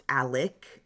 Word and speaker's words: Alex: It does look like Alex, but it is pronounced Alec with a K at the end.